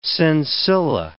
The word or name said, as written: sensilla